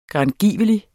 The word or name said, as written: grangiveligt